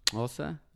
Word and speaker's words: Xhosa: It's actually pronounced Xhosa. You have to do like if you really want to speak it accordingly, that's why it has like the clicking things.